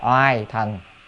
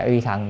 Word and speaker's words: uy thần: Chữ này đọc là oai thần hay là uy thần thì được, ai đọc oai cũng được, ai đọc uy cũng được.